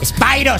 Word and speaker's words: Spiro: Y mira, ahora vamos a escuchar a Siena Spiro. – Spyro se pronuncia.